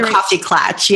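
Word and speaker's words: Coffee Klatch: And then you have Coffee and Clatch. Did I say that right? – Yeah. So the Coffee Clatch.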